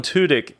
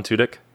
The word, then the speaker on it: Tudyk: tudyk and um, i feel like i always say his name wrong is that how you say it alan tudyk yeah, Tudyk.